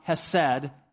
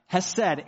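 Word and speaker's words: hesed (חסד): And I'm not going to be able to pronounce it properly, but it's pronounced hesed in English by this guy, right? There's some guttural sound there that I won't even try for you there. All right, hesed, H-E-S-E-D.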